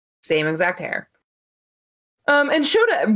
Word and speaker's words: Shota: Which they kept pronouncing his name as Shoto. It's Shota. I think Matt Raywalt was just calling him Shoto. Kevin Kelly was pronouncing it right.